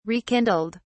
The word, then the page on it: rekindled: British English